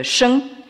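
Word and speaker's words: sheng (生): Et c'est pas la peine d'être grand clair pour s'apercevoir que l'élément principal de ce caractère se retrouve dans celui qui se prononce en chinois moderne « sheng ».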